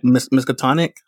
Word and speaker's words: Miskatonic: We talked to Mike about, I always mispronounce this, the name of the Miskatonic.